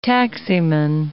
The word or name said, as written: taximan